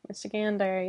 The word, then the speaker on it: Michigander: Well, I didn't know you were a Michigander. – Michigander, yeah. – Is that how you say it? – Yeah.